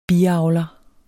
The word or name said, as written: biavler